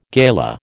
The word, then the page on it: gala: American English